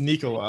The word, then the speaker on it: Nikola: I sent him Vic Lombardi's tweet video of Vic asking Jokic, like, have we been saying your first name wrong for since you've been here? And he was basically like, yeah. yeah. – So it's, it's, it's Nicola. It's Nicola. So you just say it slow.